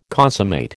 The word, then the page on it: consummate: American English